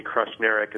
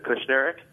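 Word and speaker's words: Kushneriuk: They called me Krushnerik instead of Kushnerik. They always thought there was an R after the K.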